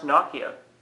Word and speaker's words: Nokia: Apparently pronounced Nokia. – Yeah, it's definitely Nokia. – Is it really? – Yeah, well, in Finnish, yeah.